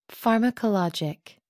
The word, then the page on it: pharmacologic: American English